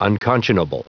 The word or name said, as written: unconscionable